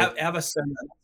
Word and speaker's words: Avicenna: But go ahead and you say Avicenna. Did I say that correctly? – Avicenna. Yeah, so his Latin name is Avicenna.